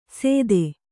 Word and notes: ಸೆತ್ತೆ: kannada

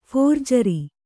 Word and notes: ಫೋರ್ಜರಿ: phōrjari

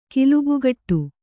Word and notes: ಕಿಲುಬುಗಟ್ಟು: kilubugaṭṭu

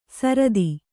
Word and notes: ಸಾರತಿ: Kannada

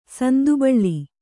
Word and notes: ಸಂದುಬಳ್ಳಿ: sandu baḷḷi

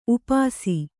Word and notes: ಉಪಾಸಿ: Kannada

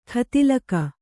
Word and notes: ಖತಿಲಕ: kha tilaka

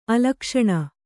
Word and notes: ಅಲಕ್ಷಣ: alakṣaṇa

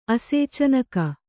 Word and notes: ಆಸೇಚನಕ: āsēcanaka; kannada

